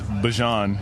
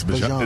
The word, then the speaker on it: Bijan: How you pronounce my name is Bijan. – Honey Bijan. – For everybody that's out there. I know a lot of people say Bijan. – There you go. – But it's Bijan.